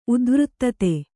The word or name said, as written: ಉದ್ವೃತ್ತತೆ